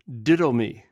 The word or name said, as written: didwmi